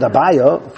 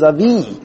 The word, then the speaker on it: Avi: So now why, we pronounce it Abaya, for whatever reason, I'm not sure why, but really the correct pronunciation is Avi.